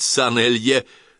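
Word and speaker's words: Saint Helier: Так вот, этот банк находится в Сент-Хельере. Если скажешь, что правильно произносить Сан-Элье, лично прилечу надрать тебе уши.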